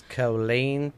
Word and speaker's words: Killeen: Is that how you say it, Killeen? – Yes, Killeen, Texas.